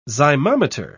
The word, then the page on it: zymometer: British English